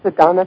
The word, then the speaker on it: Sadhana: Well, we've opened a door, and I want to make sure am I actually pronouncing this correctly, the name of the therapy. – Oh, you are. It's Sadhana Therapy.